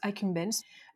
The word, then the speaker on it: accumbens: Alors vous le prononcez comme vous voulez, moi je le prononce accumbens.